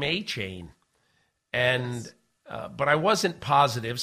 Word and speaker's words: Achane: They called him, and he said, yeah, it's Achane.